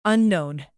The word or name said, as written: unknown